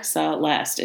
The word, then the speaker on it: Celeste: To this day, confuses me because there is no H sound in my name. – Yeah. – Celeste. It's very easy to pronounce.